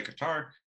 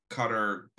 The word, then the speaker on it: Qatar: And before, obviously, Qatar, is the way it's pronounced. Some people say Qatar. There was rumors that FIFA, which is known to be a corrupt organization, kind of made sure that Qatar got to be able to host the World Cup.